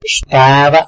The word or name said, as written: xtada